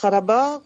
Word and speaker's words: Karabakh: Mais sinon, donc, vous pouvez prononcer Karabakh plus tard avec un R un petit peu roté.